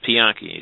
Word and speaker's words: Pianki: My name is Pianki. It's spelled P-I-A-N-K-I. – P-I-A-N-K-I.